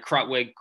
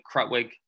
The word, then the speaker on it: Krutwig: Clearly not Loyola Chicago's sister Jean, Cameron Kurtwig. I believe I said his name right. Krutwig. Krutwig. That's his name.